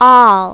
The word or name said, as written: all